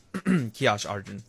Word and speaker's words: Kiash Arjun: Search Kiash Arjun.